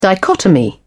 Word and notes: dichotomy: British English

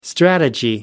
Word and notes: strategy: American English

